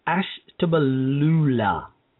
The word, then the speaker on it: Ashtabula: The sheriff's department in Alabasta County, I'm sorry, I mispronounced that. It's Ashtabula County, Ohio, has been slashed from 112 to 49 deputies.